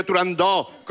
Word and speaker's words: Turandot: En el mundo exótico en el que vive Turandot, con abierta.